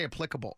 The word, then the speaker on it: applicable: I say applicable. It starts just like the apps on your phone, not applicable.